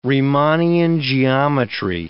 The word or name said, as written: Riemannian geometry